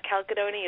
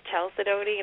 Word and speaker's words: Chalcedony: You're Someone had to email me and tell me that it's not Chalcedony, it's Chalcedony, and I was like, oh my gosh, I've been saying that forever.